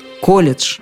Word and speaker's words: колледж: Сейчас колебаний в произношении стало значительно меньше, да и словари утвердились в том, что «колледж» надо произносить именно так – «колледж».